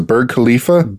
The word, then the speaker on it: Burj Khalifa: Burj Khalifa.